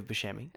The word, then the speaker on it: Buscemi: Well, I already said it, but it's Steve Buscemi.